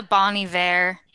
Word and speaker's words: Bon Iver: Isn't that the Bon Iver? Did I say that right? – Mm-hmm. Yeah.